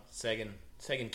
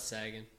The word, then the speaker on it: Seguin: I'm not Seguin. They get it wrong. The Americans don't know how to say it. – Seguin.